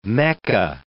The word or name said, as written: mecca